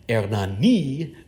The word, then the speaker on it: Hernani: It is based on a play by Victor Hugo, Hernani, accent on the last syllable.